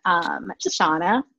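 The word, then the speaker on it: Chauna: It's a super creative business name, Shauna Bryant, everywhere. And fun fact, my name is spelled with a C. It's C-H-A-U-N-A.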